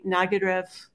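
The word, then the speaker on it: Nagyrév: I would say Nagyarev, something like that.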